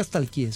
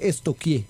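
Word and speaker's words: stalkear: Porque no se dice nunca estalquié, se dice estoquié.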